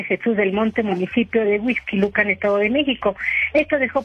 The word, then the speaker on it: Huixquilucan: Una explosión por acumulación de gas en la parte alta de una torre de departamentos allá en Huixquilucan, ese sí lo dije bien, dejó como saldo, lamentablemente, 3 personas lesionadas y algunos departamentos dañados.